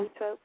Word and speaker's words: Zoetrope: The two I use online is Zoetrope. Is that how you pronounce it? – Yeah. – Zoetrope, yeah.